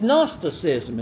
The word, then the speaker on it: Gnosticism: It's Gnosticism is what it is. The G is silent in this.